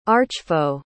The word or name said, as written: archfoe